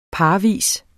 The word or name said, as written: parvis